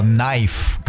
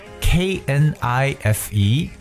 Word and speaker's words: knife: 第一个呢就是刀，手拿着一把刀，刀是不是叫 knife？可是它的拼写是 K N I F E。但是它的发音叫 knife，所以记住 k 在字母的 n 前面是不发音。